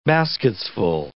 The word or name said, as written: basketsful